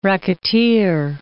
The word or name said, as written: racketeer